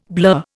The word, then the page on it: blur: Indian